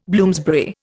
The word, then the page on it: Bloomsbury: Indian